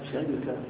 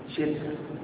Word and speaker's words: شركة: الشركة باللغة تكون على وزن سرقة ونعمة وثمرة. على وزن سرقة شركة، نعمة شركة، ثمرة شركة. تنطق هكذا وهكذا وهكذا.